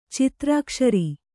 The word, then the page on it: ಚಿತ್ರಾಕ್ಷರಿ: citrākṣari